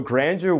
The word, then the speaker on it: grandeur: So grandeur 원래 불어인데 발음이 조금 힘들어요. 근데 우리 미국식으로 발음해요.